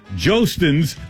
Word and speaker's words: Jostens: Yes, that's it's Jostens. That's what it says phonetically here.